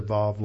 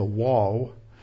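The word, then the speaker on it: Vav (ו): But when you go to Israel, they all pronounce the Vav like a V. They don't pronounce it like a W.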